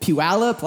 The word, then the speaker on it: Puyallup: And that is like a weird, like, Puyallup.